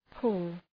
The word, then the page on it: pull: {pʋl}